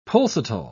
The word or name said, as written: pulsatile